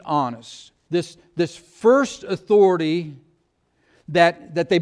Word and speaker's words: Annas: John chapter 18, verse 13, we read that first they led him to Annas.